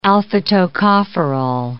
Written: alpha-tocopherol

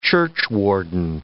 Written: churchwarden

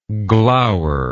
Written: gloweringly

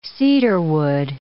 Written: cedarwood

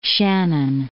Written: Shannon

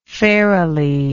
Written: fairily

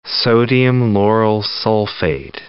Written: sodium lauryl sulfate

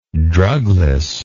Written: drugless

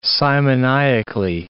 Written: simoniacally

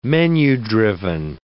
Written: menu-driven

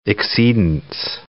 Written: exceedance

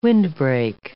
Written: windbreak

